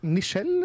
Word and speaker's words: Nichelle: Nei, jeg tror det er Nichelle, liksom, for det er jo Michelle bare med N istedenfor.